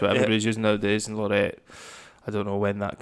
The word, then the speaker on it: Laureate: Cause so the barley they use is Concerto, and I have a really bad pronunciation of this, and you can correct me. I'd say Lorette. – Lorette. – L-A-U-R-E-A-T-E.